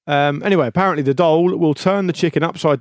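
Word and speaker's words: dhole: A dole. – D-H-O-L-E, a dole.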